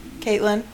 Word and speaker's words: Katlyn: That is KATLYN, Caitlin.